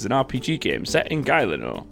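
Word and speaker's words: Gielinor: But is it Galino or Galino? – Oh, it's Galino. – Galino. It's G-I-E. – I'd never got that, to be honest. I just know L-I-N-O.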